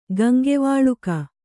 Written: ಗಂಗವಾಲುಕ

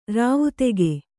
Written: ರಾವುತೆಗೆ

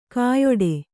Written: ಕೈಯಿಡು

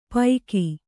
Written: ಪೈಕಿ

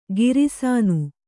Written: ಗಿರಿಸಾನು

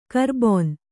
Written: ಕರಬೋನ

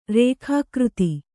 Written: ರೇಖಾಕೃತಿ

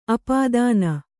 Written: ಅಪದಾನ

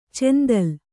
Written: ಚೆಂದಲ್

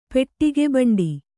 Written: ಪೆಟ್ಟಿಗೆಬಂಡಿ

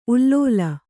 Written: ಉಲುಲು

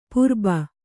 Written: ಪುರ್ಬು

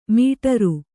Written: ಮೇಢ್ರ

